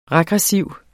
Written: regressiv

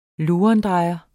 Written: lurendrejer

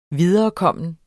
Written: viderekommen

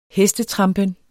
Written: hestetrampen

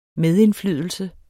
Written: medindflydelse

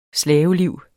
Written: slaveliv

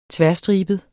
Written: ternet

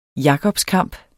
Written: jakobskamp

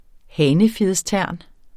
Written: hanefjedstern

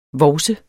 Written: VOVSERNES

